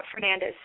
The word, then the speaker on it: Fernandez: my email is my first name, .Fernandez.